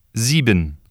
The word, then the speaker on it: sieben: When the letter S precedes a vowel or appears between two vowels, it is voiced or pronounced like the English Z. Listen and repeat. – Sehen, sieben, Sohn, sehr, suchen, lesen, Häuser, Musik.